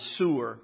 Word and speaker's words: soor: Now, the Hebrew word for turning aside is soor. You would spell it S-O-O-R if you transliterate it into English.